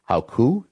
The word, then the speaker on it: Hochul: And you can correct me if I'm getting her name wrong, Lieutenant Governor Kathy Hochul. Is that correct? – Local rhymes with local.